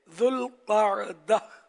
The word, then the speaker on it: ذوالقعدہ: ذوالقعدہ دو لفظوں سے مل کر بنا ہے, ایک ذو اور دوسرا القعدہ, اس کا درست تلفظ یہ ہے ذوالقعدہ.